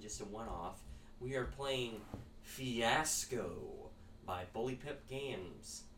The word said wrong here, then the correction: Bully Pulpit Games: Bully Pulpit Games.